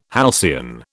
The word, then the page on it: halcyon: British English